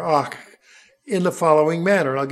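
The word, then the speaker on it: ach (אך): It's spelled A-K. It's pronounced ach. Sort of like my German grandmother would say, ach, you.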